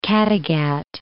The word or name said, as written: Kattegat